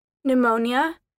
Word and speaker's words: pneumonia: The P isn't pronounced at the beginning of many words, particularly those starting PS or PN. Pneumonia, pneumatic, psychotherapy, pseudonym, psychotic, psychiatrist, psychologist.